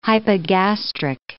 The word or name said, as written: hypogastric